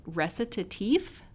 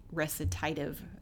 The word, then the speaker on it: Recitatif: It is. I had to look it up online, how to say it. It's the French version of, you know, I guess you would say recitative in English or whatever.